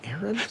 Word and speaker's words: Aaric: A-A-R-I-C is his name, Arik. – Why didn't you say it the first time I said Aaron? – Because it's pronounced Aaron.